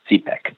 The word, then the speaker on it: CPEC: In Pakistan, it's called CPEC, or China-Pakistan Economic Corridor, so CPEC, pronounced CPEC. And China is investing very heavily in Pakistan.